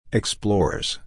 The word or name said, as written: explores